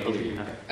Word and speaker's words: Valyrian: Valyrian. – Okay, Valyrian.